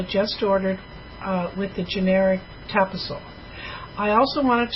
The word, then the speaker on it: Tapazole: How do you say that? – Tapazole, so yes.